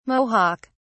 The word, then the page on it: mohawk: British English